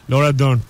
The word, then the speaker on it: Laura Dern: E, Mark Hamill, e, Carrie Fisher, Laura Dam, Oscar Isaac. Yani, Laura Dern mi yoksa o ya? He, olabilir. RN mi o?